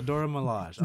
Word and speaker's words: Dora Milaje: Yeah, the Dora Milaje. Okay, I said it right.